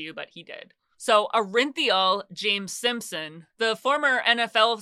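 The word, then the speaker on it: Orenthal: Yes, this is how he pronounces it. I know a lot of people say Orinthol, but he pronounces it Orinthiol, or he pronounced it.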